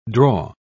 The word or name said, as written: draw